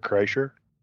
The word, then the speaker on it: Kreischer: A very popular thing like this is The Machine and Bert Schneider. – Kreischer. – Whatever his name. Yeah, him, that dude, Bert. I can't remember. How do you say his last name again? – Bert Kreischer.